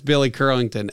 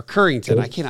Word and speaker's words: Currington: I think that's Billy Curlington. Or Currington. I never pronounce it right.